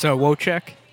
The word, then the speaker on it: Wojcik: It's Wojcik.